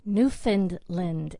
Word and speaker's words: Newfoundland: Newfoundland is how you say it. – New F-O-N-U-N-D.